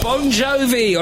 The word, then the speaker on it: Bon Jovi: Bon Jovi on Absolute Radio, Rock and Roll Football.